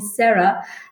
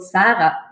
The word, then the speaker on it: Sarah: I always let everyone know that I'm very happy if they just call me Sarah. And which, by the way, is also not the German pronunciation. Of course, in German, I'm called Sarah.